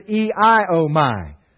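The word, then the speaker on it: eiomai (ἰάομαι): That normal Greek word is eiomai. Now, it's not spelled that way, but if you want to write that down, I wrote it down this way, E-I-O-M-Y. Eiomai. That's the way it's pronounced.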